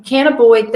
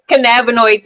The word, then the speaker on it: cannabinoid: So cannabis, but cannabinoid.